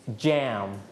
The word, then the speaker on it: jam: บางคนออกเสียงว่าแยมบางคนออกเสียงว่าแจม both are correct if you call it in Thai you may call it like yam but in English you can call it like jam